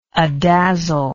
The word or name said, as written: adazzle